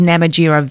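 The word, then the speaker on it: Namatjira: The phonetic pronunciation is Namajira, Vincent Namajira.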